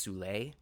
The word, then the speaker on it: Soule: I believe his last name is pronounced Soule, S-O-U-L-E. I think it's Soule.